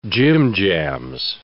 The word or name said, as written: jimjams